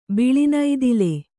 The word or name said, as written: ಬಿಳಿನೈದಿಲೆ